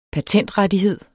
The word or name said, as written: patentrettighed